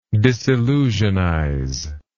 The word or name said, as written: disillusionize